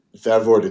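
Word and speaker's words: Vervoordt: And that's spelled H-J-O-R-T-H is the surname, isn't it? Is that how you say it? – Vervoort. It's V-E-R-V-O-O-R-D-T.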